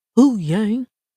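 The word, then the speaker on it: Huyang: Saves Hu Yang. You gotta say it like that. Hu Yang.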